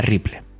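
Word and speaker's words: REPL: que a menudo se pronuncia de manera similar a Ripple la parte de read, MicroPython espera que se escriba un comando y luego se presiona la tecla intro.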